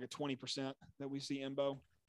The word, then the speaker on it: Embo: Oh, Imbo, yeah. Good call. – His name's Imbo. – How you spell that? – E-M-B-O. Imbo.